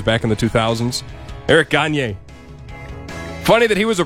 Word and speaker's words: Gagne: Credit Union Phil, Eric Gagne. I mispronounced. I know it's pronounced Gagne.